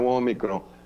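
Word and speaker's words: Ômicron: E o senhor, como médico, eu queria que o senhor falasse sobre essa nova cepa, que é, é Omicron, né, que se pronuncia? – É, o Omicron.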